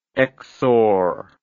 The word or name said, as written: xor